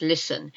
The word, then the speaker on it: listen: But there's a silent T in listen. Did you know that? L-I-S-T-E-N. Listen. It's not listen. Listen. Listen, listen to me. Listen to me and you'll get it right.